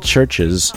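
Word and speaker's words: Chvrches: Start off the whole set with a band called Churches, and it's spelled with a V.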